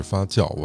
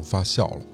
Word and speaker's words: 发酵: 我看上上期的留言也有不少朋友跟我说，应该是发酵，我读成发酵了酵素嘛，这字儿认识，读白了，谢谢大家给我指出来哈。